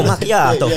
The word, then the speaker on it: macchiato: That's how you say it as well, macchiato.